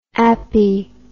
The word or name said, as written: appie